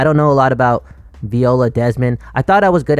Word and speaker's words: Viola Desmond: I'm pretty sure it's Viola Desmond.